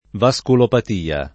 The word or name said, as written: vasculopatia